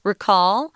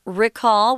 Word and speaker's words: recall: Recall. Uh, the, the verb pronunciation, guys, is recall with a short I sound, but if it's a noun, recall then uh, then you're going to pronounce it differently because English likes to mess people up, huh?